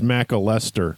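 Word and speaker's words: McAlester: So McAllister, Oklahoma, that's how they get you. Don't go to the hospital over there. – I think it's pronounced McAllister. – That's probably more correctly.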